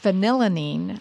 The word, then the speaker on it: phenylalanine: The problem with phenylalanine, I think that's how it's pronounced. – I think you're right, yes.